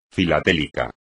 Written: filatélico

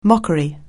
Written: mockery

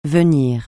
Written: venir

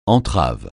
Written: entrave

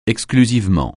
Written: exclusivement

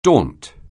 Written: daunt

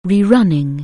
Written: rerun

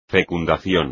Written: fecundación

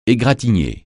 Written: égratigner

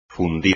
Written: fundir

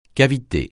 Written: cavité

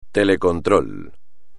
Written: telecontrol

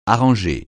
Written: arranger

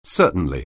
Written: certainly

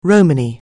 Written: Romany

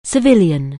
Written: civilian

